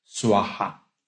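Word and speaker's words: Svaha: Se escribe con V, pero se pronuncia como una U. Suaja.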